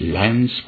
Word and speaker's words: Lance: Mais supposons, pour en revenir à notre authentique champion, que, par un miracle que je ne pourrais pas expliquer, Lance Armstrong, faut le prononcer juste Lance,